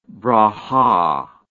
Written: brachah

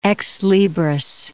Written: ex libris

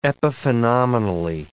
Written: epiphenomenally

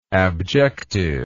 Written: abjective

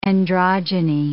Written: androgyny

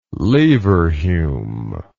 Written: Leverhulme

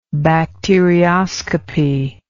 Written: bacterioscopist

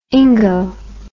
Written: ingo

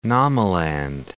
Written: Namaland

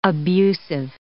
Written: abusive

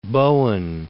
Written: Bowen

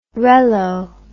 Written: rello

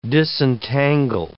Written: disentangle

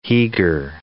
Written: Heeger

